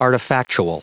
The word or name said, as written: artifactual